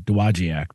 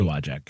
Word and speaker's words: Dowagiac: Yeah, that G-I-A-C at the end there is the tough part. – Dowagiac. Yeah, I always pronounced it phonetically Dowagiac, but it's so Dowagiac.